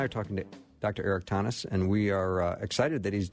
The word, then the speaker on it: Thoennes: It's Thoennes, like Thomas with an N, Thoennes.